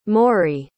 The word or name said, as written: Maury